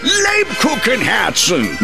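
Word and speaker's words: Lebkuchenherzen: Lubkuchen Herzen. – The Kupen Herkens Herzen. – Lubkuchen Herzen. – Nein! Lebkuchen Herzen.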